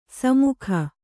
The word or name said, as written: ಸುಮುಖಿ